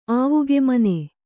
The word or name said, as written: ಆವುಗೆಮನೆ